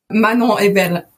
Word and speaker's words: Manan Ebel: And let's hear how your name is pronounced in the proper French. – Manan Ebel.